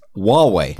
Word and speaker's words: Huawei: there's no H. It's Huawei. – Huawei. – Yeah. – I don't agree, but okay. space right now. – It's Huawei. – Huawei. – Huawei. That's how you say it. – Huawei. – Yeah, not Huawei. – I hate this right now. – No, you don't. – It's got an H in it. – It's a silent H.